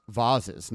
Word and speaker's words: vases: And don't forget the two vases, pronounced vases. Not that Mariah cares how you pronounce it.